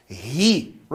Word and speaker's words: he: Mas no inglês tem. Né? Agora, ah, mas eu já ouvi gente falando é, sem a pronúncia. É na Inglaterra, ali no sul da Inglaterra, ali, realmente eles não pronunciam o H, tá? Mas como eu falei para vocês, a gente está estudando aqui o inglês dos Estados Unidos, tá? Então o americano ele fala he.